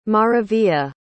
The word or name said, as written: maravilla